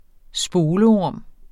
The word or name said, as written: spolorm